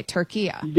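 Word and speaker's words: Türkiye: And so now the instruction is that also in English, say it like how it's essentially supposed Turkiya. – To be pronounced. Okay, Turkiya. – There you go. That's perfect. That's how it's pronounced.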